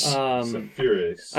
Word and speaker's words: Seferis: S-E-F-E-R-I-S. – Sepheris? – Sepheris.